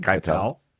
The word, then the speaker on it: Keitel: Keitel.